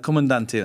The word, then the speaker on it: Comandante: How do you pronounce it? – Comandante.